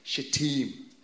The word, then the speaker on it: Shittim: This is how you pronounce the word, Shittim.